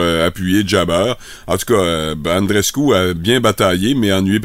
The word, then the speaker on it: Andreescu: Tennis, la Canadienne Bianca Andreescu, oui, c'est comme ça que ça se prononce, Jean-Michel, a perdu son match de troisième tour contre la Tunisienne Hans Jabeur hier à Montréal.